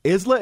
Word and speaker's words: Isla: Isla? Am I saying it right? – Yeah, no. You're saying it perfectly. He said it perfectly.